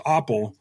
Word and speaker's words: Uppel: I've been raised my whole life as it's pronounced Oppel. It's German, but we're pretty far out from, like my great-great-great-grandfather came over here from Germany, so it's, I have no idea.